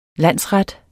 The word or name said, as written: landsret